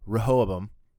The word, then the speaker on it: Rehoboam: So now we are getting Solomon versus Rehoboam.